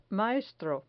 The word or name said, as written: maestro